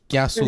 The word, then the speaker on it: kiasu: It's fear of, is it kiasu? Or what was the word? – Kiasu. – Mm-hmm. Kiasu, yeah. – You kind of pronounce the K and the A and I through your nose.